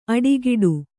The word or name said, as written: ಅಡಿಗೀಟು